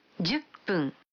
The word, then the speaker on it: 分: 10分. – Habrán notado que cuando... – 10. – 10 viene antes de... – 分. – Se pronuncia pun en vez de hun.